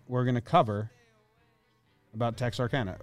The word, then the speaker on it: Texarkana: The authentic way to pronounce it is Texarkana. Okay.